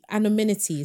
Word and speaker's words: anonymity: The anonymity, is that how you say it? – Yeah.